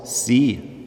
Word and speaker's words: C: C auf Englisch spricht man aus, sie.